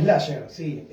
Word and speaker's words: Slasher: Slasher, sí. Se escribe como Slash, como el que toca la guitarra en Guns N' Roses, Slasher.